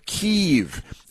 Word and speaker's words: Kyiv: Kyiv, like K-E-E-V-E or something, and I always refuse to call it Kyiv because that's ridiculous. It's called Kiev.